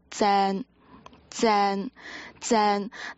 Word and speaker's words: 正: 那这个字呢在粤语里面我们会也算是一个非常经常会听到的一个词，那这个字的读音呢就叫 zen zen zen，那对比起我们的普通话那个发音就是叫正正，就是正方形的那个正正正。